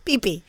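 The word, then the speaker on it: Peepee Township: And it's spelled P-E-E. Wait a minute. P-E-E-P-E-E Township. – PP.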